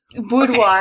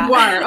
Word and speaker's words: boudoir: As I understand it, it's a boudoir. Am I saying that right? Boudoir photography? – Yeah. Boudoir. – Okay. Yeah. Boudoir.